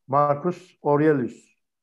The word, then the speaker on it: Marcus Aurelius: Aliye İzzet Begoviç belki o efsanevi e, Roma imparatoru ve gerçekte bilge kral denilen ilk e, kişi olan Marcus Aurelius. Doğru telaffuz ettim mi? Abi Marcus Aurelius evet.